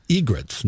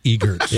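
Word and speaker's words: egret: Well, because the R is in a different spot. And, They're actually egrets, not egerts.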